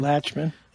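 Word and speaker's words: Lachman: Like Pat Leachman, is that how you pronounce the last name? – Latchman. – Yeah.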